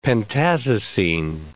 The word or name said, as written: pentazocine